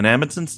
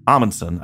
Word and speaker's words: Amundsen: I thought it was Amundsen, I believe, is correct. Although in Edward's full reading, he says Amundsen. – Oh. – So I think you might be able to pronounce it a few different ways, but we'll say.